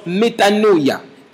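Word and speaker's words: metanoia (μετάνοια): Le mot repentez-vous ici, le mot repentir, est un mot grec que l'on prononce metanoia, qui veut simplement dire, changez votre façon de penser.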